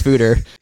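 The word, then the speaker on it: foeder: So we had to research, A, how the hell do you pronounce Fooder? It's a Dutch word.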